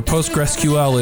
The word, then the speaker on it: PostgreSQL: He is a member of the core team, the Postgres core team, or PostgreSQL is how he explained it's pronounced.